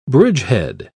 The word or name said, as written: bridgehead